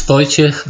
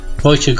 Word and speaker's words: Wojciech: It's Wojciech Golchewski.